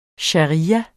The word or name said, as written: sharia